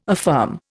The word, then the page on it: affirm: Indian